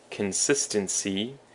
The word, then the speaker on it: consistency: And moving on, we have consistent, consistency, and inconsistent. So consistent has the emphasis on the sis, consistent. It's an adjective that means 始终如一的 or 一致的. Consistency also has the emphasis on the second syllable, and it's a noun that means 一致.